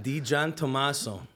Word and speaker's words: DiGiantommaso: DiGiantommaso. – DiGiantommaso. – Yeah. In English, it's English in the front and Italian in the back.